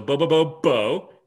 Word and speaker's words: Bobobo-bo: Hyphen B-O-B-O. – So, okay. – Bo-bobo. – Bo-bobo. Okay. It's actually three bows, hyphen bow. So Bo-bobo-bo, and then there's a space, and then bo hyphen bobo.